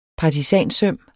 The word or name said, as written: partisansøm